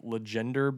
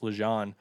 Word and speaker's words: LeGendre: And then Lance Lejeune, it's spelled Legender, basically, is how it looks if you're looking at it, but I believe it's pronounced Lejeune.